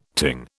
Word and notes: ting: British English

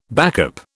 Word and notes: backup: British English

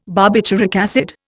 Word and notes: barbituric acid: Indian

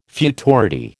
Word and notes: futurity: American English

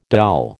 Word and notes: doll: American English

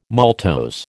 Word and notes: maltose: American English

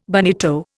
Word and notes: bonito: Indian

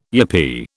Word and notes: yippee: British English

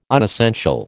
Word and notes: unessential: American English

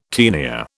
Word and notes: tenia: British English

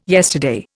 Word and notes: yesterday: Indian

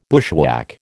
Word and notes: bushwhack: American English

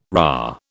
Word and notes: RA: British English

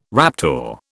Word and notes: raptor: British English

